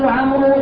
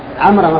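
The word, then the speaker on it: عمرًا: لا عمرا مفعول به الواو هذه تكتب ولا ينطق بها. لماذا تكتب يا جماعة؟ للفرق بين عمر وعمر. ولهذا إذا كانت عمرا منصوبة ها؟ لا تكتب الواو لأن التنوين فرق.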